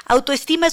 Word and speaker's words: autoestima: Autoestima es una palabra grave, lleva el acento en su penúltima sílaba y su A inicial es átona, es decir, se pronuncia sin acento de intensidad.